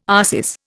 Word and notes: arsis: Indian

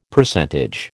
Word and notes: Percentage: American English

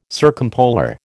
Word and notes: circumpolar: American English

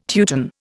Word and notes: Teuton: Indian

